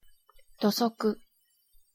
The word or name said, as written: dosoku